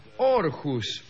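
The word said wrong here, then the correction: Århus: Sí, se pronuncia Erjus, que es distinto, ah, pero bueno.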